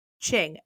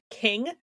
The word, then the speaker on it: Qing: I think before I would have pronounced it King, because I know Q doesn't make like the Q sound, so I probably would have said King, but it's Qing.